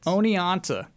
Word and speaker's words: Oneonta: Onianta, O N E O N T A, Onianta, New York.